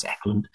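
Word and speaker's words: Ekland: Directed by Mike Hodges, starring Michael Caine, Ian Hendry, and Britt Ackland. Auckland. I always get the name wrong. It's Ackland.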